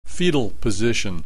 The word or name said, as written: fetal position